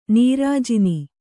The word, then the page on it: ನೀರಾಜನ: nīrāj`ana